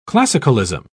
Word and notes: classicalism: British English

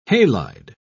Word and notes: halide: British English

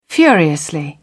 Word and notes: furiously: British English

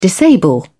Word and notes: disable: British English

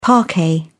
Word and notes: parquet: British English

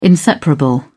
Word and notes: inseparable: British English

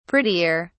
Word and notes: prettier: British English

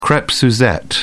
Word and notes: crepe suzette: British English

